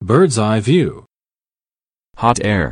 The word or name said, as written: bird’s-eye view